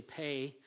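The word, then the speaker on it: pay (פ): It looks like PE, like physical education. It's really pay is how it's pronounced.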